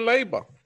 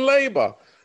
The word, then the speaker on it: Leyba: She's in Leyba. I had to learn that comment. She's in Leyba.